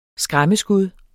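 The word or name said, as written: SKRÆMMESKUD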